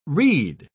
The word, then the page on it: rede: British English